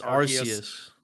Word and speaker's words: Arceus: Is it Arceus, Arceus, Arceus, it's Arceus. – Arceus. – Arceus. – It's pronounced Arceus.